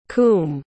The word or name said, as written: coombe